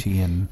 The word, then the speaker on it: Omartian: And how did we decide her name is pronounced? Stormie. – Omardian. – Omardian. Omartian. O M A R T I A N opposed to a Martian. That is not correct.